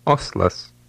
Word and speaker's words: Oslas: Listen and repeat number eight, paying attention to how the L in this word is pronounced. – Oslas. Oslas. – When the L in Lithuanian is followed by an A or an O, it does resemble the English L and is called a hard L.